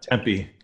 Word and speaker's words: Tempe: Tempe, Arizona, if I say that right. – Tempe, Tempe, yeah.